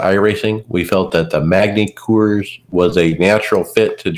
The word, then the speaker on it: Magny-Cours: It is in France, as they tout on the teaser, and looks like it's the longtime home of the French Grand Prix, Magny-Cours. Is how I'm pronouncing it, whether Coors. It's right or not. – Coors. – Okay. Well, I thought I didn't know they pronounce hard R's in French that much.